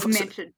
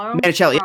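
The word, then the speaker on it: Manganiello: Joe Manda. So Manicello. – Manicello.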